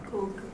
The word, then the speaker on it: Coecke: Koch, non so come si pronunci, C-O-E-C-K-E. – Koch.